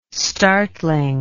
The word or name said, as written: startling